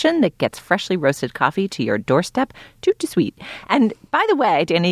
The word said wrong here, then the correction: tout de suite: What did you just say? It gets it there how? – Tout de suite. Dana, say it yourself. Do you just say tout de suite? – I think in general the duh is kind of elided in pronunciation.